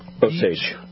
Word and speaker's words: Osage: Then we have KRMS, 1150 AM are the translators, and we've got 97.5 FM and 103.3 FM in Osaga? – Osage. – Osage? I can never say it right, and I apologize. – Osaga sounds too much like that city Osage.